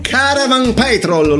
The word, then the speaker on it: Caravan Petrol: Caravan Petrol, l'ho pronunciata bene Marco? Si pronuncia così.